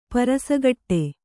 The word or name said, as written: ಪರಸಗಟ್ಟೆ